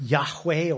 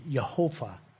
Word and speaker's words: Yahweh: You know, we need vowels to be able to read, but it's pronounced Yahweh or Yehovah.